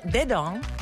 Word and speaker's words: dedans: It's called Dedong, which means inside, and it's by Azaria and MNJ. Now, if you get on YouTube, you'll see Dedans. It's spelled D-E-D-A-N-S.